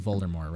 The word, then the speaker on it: Voldemort: Rowling and said, hold on, the T is silent in Voldemort, right?